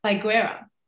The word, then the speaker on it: Baiguerra: And on the show today, I have the lovely Paul Baiguerra. Did I get it right? – You did that well.